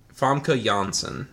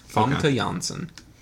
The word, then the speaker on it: Famke Janssen: Famke Janssen is how you pronounce it. – Okay. – Famke Janssen.